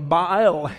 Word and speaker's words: Baal: No, what he was saying was, you know, they worshiped Baal, or Baal is the way it's pronounced.